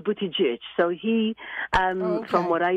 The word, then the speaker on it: Buttigieg: But it's pronounced Buttigieg, and this poor man thinks his name is Buttigieg.